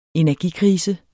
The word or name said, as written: energikrise